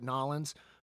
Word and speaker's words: New Orleans: Okay, if you're from New Orleans, you call it Nollins.